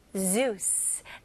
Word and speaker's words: Zeus: The Greek myth is about Zeus. 네, 제우스라고 하지만 영어로는 Z 발음으로 Zeus.